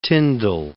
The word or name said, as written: Tindal